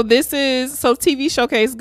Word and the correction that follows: TV Shows Ace: I'm saying this wrong. It's TV Shows Ace. Yes, TV Shows Ace.